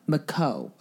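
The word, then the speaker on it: Micheaux: Miko. Miku. It looks like Michael, but then it's an E-A-U-X. – Oh, Miko. – Miko.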